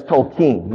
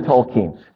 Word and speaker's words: Tolkien: And then I learn that it's Tolkien. You know, I'm keen on Tolkien.